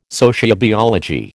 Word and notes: sociobiology: American English

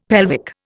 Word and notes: pelvic: Indian

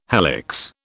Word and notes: helix: British English

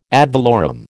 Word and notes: ad valorem: American English

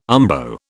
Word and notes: umbo: British English

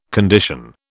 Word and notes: condition: British English

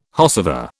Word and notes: whosever: British English

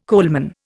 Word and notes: coalman: Indian

